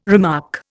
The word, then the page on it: remark: Indian